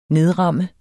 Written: nedramme

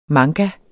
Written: manga

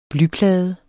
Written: blyplade

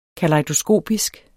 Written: KALEJDOSKOPISK

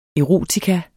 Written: erotika

